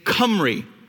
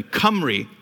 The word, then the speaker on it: Cymru: Now, croeso means welcome to, and then the way you pronounce that word, G-Y-M-R-U, is Cymru, like a C or a K. All right, so I want you to say that after me. Cymru.